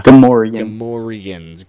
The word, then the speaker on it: Gamorreans: Gamorreans.